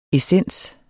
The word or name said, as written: essens